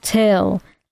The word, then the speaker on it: Thgil: And he goes, my name is Till, spelled T-H-G-I-L, but it's pronounced Till.